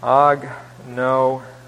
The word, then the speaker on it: agnoeo: Agnoeo. A-G-N-O-E-O, and that last O is the omega, the long O here.